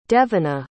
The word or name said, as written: devyna